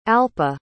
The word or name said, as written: alpa